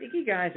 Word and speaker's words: Ikigai: But Ikigai is a word that, and it's pronounced Ikigai, but it's actually a phrase that comes out of Okinawa.